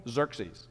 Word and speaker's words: Xerxes: Xerxes.